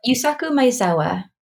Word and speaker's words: Yusaku Maezawa: Yusaku Maezawa.